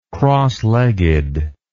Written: crossleggedness

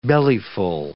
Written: bellyful

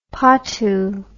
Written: patu

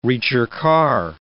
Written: ricercar

